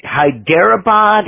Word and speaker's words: Hyderabad: Hyderabad in India? – Yep.